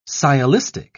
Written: sciolistic

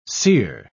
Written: seer

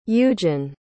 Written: eugen

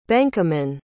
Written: benkamin

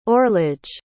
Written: orlege

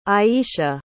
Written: iesha

